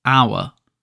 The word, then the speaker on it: hour: So hour starts with a H, although here we pronounce it hour, so we're pronouncing it without the H.